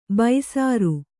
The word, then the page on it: ಬೈಸರು: Kannada